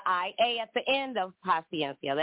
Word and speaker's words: paciencia: Por su, the last word is paciencia. And in Spanish, it's spelled P-A-C-I-E-N-C-I-A.